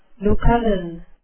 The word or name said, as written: lucullan